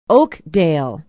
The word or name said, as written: oakdale